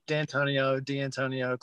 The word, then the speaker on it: D'Antonio: Did I say that right? Have I been butchering your name? How do you pronounce it? Do you care? – D'Antonio, close enough.